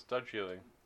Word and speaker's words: Darjeeling: No, it's Darjeeling.